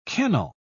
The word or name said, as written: kennel